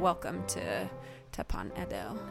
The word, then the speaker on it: Edo: And I'm going to be so bad at pronouncing this, but is it Teppan Edo? – I think it's Edo. – Edo. – I think the E is like a E. – Okay. – Because Edo.